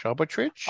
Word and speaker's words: arbitrage: I can never pronounce this. It's arbitrage? Arbitrage? – Arbitrage.